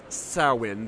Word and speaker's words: Samhain: You've heard of Samhain, or it's pronounced Samhain. The M is silent.